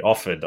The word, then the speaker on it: Offord: O-F-F-O-R-D, Offord. I don't want to say her name wrong. Offord.